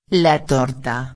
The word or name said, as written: la torta